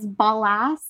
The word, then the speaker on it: ballast: It wasn't until after 1804 the city finally decided to use, I don't know if I'm pronouncing this right, but bal- blast stone. Sick. Um, yeah. that sounds good to me. Yeah, it's ballast, ballast.